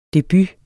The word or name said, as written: DEBUTERNES